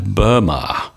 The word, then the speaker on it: Burma: Myanmar, das früher mal unter der britischen Herrschaft Birma oder Burma geheißen hat, oder besser Birma oder Burma geschrieben wurde, ausgesprochen haben die Briten das Land Burma. Burma, und das kann man in der Tat entweder Birma oder Burma schreiben aber ausgesprochen Burma.